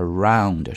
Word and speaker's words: round: 然后大家在读音的时候注意一下，这个 fund 一定要窄一些，而 round 是 out 这个音啊。